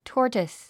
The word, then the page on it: tortoise: tɔ́ːrtəs; トータス